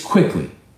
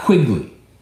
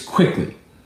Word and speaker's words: Quickly: But, like, it's Quickly. It's not Quigley. It's Quickly.